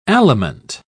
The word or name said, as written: aliment